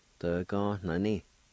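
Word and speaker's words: dvganani: It's pronounced like this. D-ga-na-ni. D-ga-na-ni. D-ga-na-ni.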